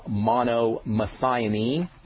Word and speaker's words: monomethionine: Selenium chelate is good, or selenium monomethionine. That's a good one, and that's spelled M-O-N-O-M-E-T-H-I-O-N-I-N-E.